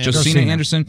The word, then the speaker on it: Josina Anderson: Is it Josina Anderson? Am I pronouncing it? – Josina Anderson. Yeah.